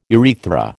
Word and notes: urethra: American English

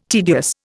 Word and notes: tedious: Indian

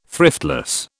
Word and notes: thriftless: British English

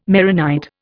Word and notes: Maronite: Indian

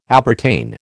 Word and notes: appertain: American English

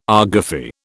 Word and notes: argufy: British English

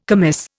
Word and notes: commis: Indian

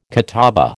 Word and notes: catawba: American English

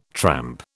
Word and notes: tramp: British English

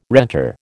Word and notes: renter: American English

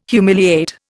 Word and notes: humiliate: Indian

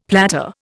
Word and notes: platter: Indian

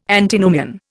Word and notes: Antinomian: Indian

